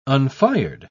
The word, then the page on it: unfired: British English